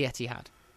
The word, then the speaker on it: Etihad: The Etihad. – How do you say that? How do you spell it? – I don't know how you spell it. It's pronounced the Etihad.